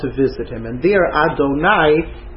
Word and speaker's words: Adonai: there's one time in Chumash where the letters Aleph, Dalet, Nun, Yud are pronounced Adonai with a patach under the Nun as opposed to a kamatz.